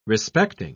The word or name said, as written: respecting